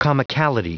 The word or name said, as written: comicality